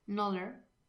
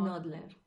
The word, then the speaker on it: Knödler: Knödler. ¿Cómo es como...? – Knödler. – Knödler. – Lo pronuncias de puta madre al principio.